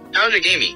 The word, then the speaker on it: Outagamie: Outagamie. – Outagamie. – Outagamie.